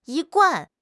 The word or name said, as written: 一贯